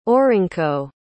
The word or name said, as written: orynko